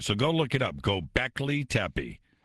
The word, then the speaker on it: Göbekli Tepe: Göbekli Tepe. – No, Göbekli. Göbekli Tepe.